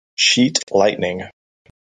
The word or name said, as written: sheet lightning